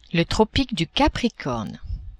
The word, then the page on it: Tropique du Capricorne: French